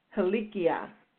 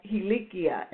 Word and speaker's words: helikia: And that word, that Hebrew word is pronounced helikia. Again, that's helikia, and that's H-E-L-I-K-I-A, and that's maturity.